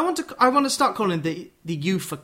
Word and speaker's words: UEFA: UEFA. I'll write down YOOFA. UEFA. – Yes. That would be the pronunciation.